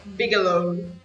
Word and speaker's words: Bigelow: Bigelow.